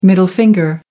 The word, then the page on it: middle finger: American English